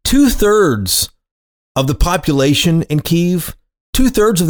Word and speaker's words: Kyiv: And if you're like me, though, you always probably heard Kyiv pronounced as Kiev. That's the Russian pronunciation. Kyiv is how the locals, Ukrainians, pronounce it. So that's why you hear so many people shifting that pronunciation to Kyiv as opposed to Kiev.